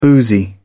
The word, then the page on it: boozy: British English